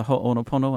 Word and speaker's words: Ho'oponopono: Okay, it's based on the Ho'oponopono, and I hope I've pronounced that correctly. – Yes, that's correct.